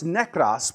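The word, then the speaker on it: nekros (νεκρός): Now, the word that Paul uses here in the Greek that's for dead is pronounced nekros, which is derived from a word nekis, and that word literally means a dead body, right?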